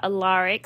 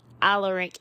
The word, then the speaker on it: Alaric: He says it's not Alaric, it's Alaric.